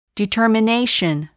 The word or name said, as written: determination